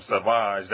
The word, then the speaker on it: Savage: She brought this little kid on a mission to go find Maul and his brother Savage, or you call him Savage. That's how you pronounce it. – Yeah. That's how they pronounce it on the show, yeah.